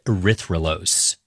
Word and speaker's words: erythrulose: And they scanned it for radio signals of different molecules, which is how they found a four-carbon sugar called erythrulose. – I've heard of, like, glucose and fructose, but, like, how do you say that? Erythrulose.